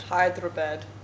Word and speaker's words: Hyderabad: And then I went to, is it at Hyderabad Hotel? Is that how you say it? – Mm-hmm. – That's what I've been saying. – Oh, Hyderabad? – Hyderabad.